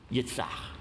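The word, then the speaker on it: Yitzchak: The Hebrew word for laughter is yitzach. Yitzach. Pronounced in English, Isaac.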